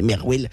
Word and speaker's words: MRWL: Et en fait, Marwal, c'est l'acronyme MRWL. Du coup, moi au début, que je connaissais pas trop Marwal, je me dis mais comment on prononce ça? En fait, c'est juste on peut prononcer Merwell.